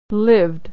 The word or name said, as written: lived